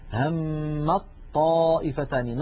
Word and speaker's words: همت طائفتان: لاحظوا لا أنطق التاء أبدا، همت طائفتان، ننطق طاء مشددة تحولت التاء إلى طاء ثم أدغمت الطاء في الطاء فصار النطق بطاء مشددة، هذا كله يا إخوتي في حال الوصل.